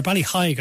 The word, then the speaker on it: Ballyheigue: after Ballyheigue, I should say, to pronounce Ballyheigue. it correctly.